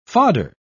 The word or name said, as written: fodder